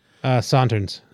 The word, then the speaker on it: Sauternes: Sauternes.